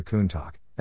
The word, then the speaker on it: Countach: El apodo de Countach, Una expresión adecuada de sorpresa y asombro en el dialecto piemontés, y se pronuncia Kuntak, es uno de los pocos nombres de modelos de Lamborghini que no están relacionados con los toros.